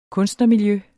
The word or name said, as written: kunstnermiljø